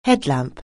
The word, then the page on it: headlamp: anglais